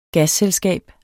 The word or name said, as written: gasselskab